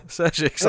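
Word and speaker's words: Šejić: Justice League Odyssey issue 2, Joshua Williamson and I was going to try to say it properly. Sejic. – Yeah. – Sejic's on art.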